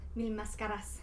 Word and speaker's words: Mil Máscaras: Máscaras. I said that right? – No. – How do I say that? – Mil Máscaras.